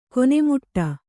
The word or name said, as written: ಕೊನೆಮಟ್ಟಿ